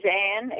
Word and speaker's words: Anne: Oh, my name is Ann, A-N-N-E, Samas, S-A-M-A-S.